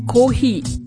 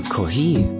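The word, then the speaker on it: Kohi (コーヒー): Kofi o kudasai. – Acht hier genau auf die Aussprache. Auch wenn es ein englisches Wort ist, sagen wir Kohi. – Ah, Kohi.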